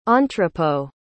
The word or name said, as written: entrepôt